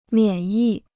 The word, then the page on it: 免疫: miǎn yì